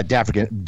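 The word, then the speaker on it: D'African: D, yeah, Dafrican. D oh. apostrophe A F R I C A N. – See, I would have taken that as the African or D African. I don't know. Dafrican.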